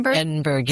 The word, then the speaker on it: Edinburgh: How do you say with it? – Edinburgh? – Edinburgh, yes.